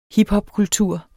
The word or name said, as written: hiphopkultur